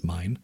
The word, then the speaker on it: Milne: And finally, one that should be of real interest to urbanists, which is called Uncivilized Genes, that's G-E-N-E-S, by Gustav Meine. M-I-L-N-E is his last name. I think it's pronounced Mine.